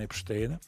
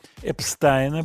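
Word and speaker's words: Epstein: Окерлунд ушел, появилась Сара Шугерман – Сара наверняка снимет хороший фильм про Брайана Эпштейна. Эпстайна, будем говорить правильно.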